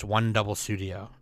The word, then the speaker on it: 100 Studio: Entertainment Planning, Production, and Human Resources Management Company Crest announced on Monday that it has founded a new digital animation studio named 100 Studio, pronounced One Double Studio.